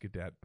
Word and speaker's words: Gadot: It's pronounced Gadot, by the way, not Gal Gadot.